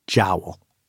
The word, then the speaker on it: Joule: The joke's on him and his heirs, though, because he pronounced his last name Jowl.